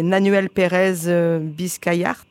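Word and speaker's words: Nahuel Pérez Biscayart: C'est Nanuel Pérez Biscayart.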